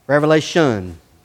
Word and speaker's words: Revelation: Revelation, no S. I said it right, Brother Cal. I don't know why I always want to put S. It just rolls off the tongue that way.